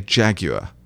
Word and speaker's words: Jaguar: And I said, in London, we say Jaguar, which is exactly how we say it. Jaguar.